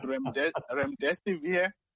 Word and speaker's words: Remdesivir: It's called Remdesivir.